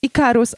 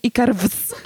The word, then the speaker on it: Ikarus: Wiecie, że ja dopiero jakiś miesiąc temu strzeliłam, że to jest Ikarus, a nie Ikarwz.